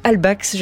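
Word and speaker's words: Halbwachs: Vous êtes sociologue affilié au centre Maurice Albax.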